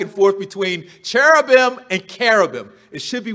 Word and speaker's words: cherubim: Now, I'm going to tell you right up front that the word is pronounced carabim or carob. You're going to hear a lot of my Baltimore E's coming out because when I see C-H, my mouth says ch, but it should be k.